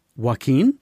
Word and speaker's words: Joaquin: Joaquin Phoenix won for the best leading actor on Joker. – What did you just say? – Joaquin Phoenix. – Joaquin? – What is his real name? – Joaquin? – Joaquin.